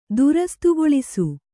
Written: ದುರಸ್ತುಗೊಳಿಸು

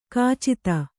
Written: ಖಚಿತ

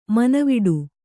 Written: ಮೌನವಡು

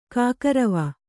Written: ಕೈಕುರುವೆ

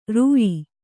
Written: ರೂಯಿ

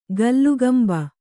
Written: ಕೀಲ್ಕಂಬ

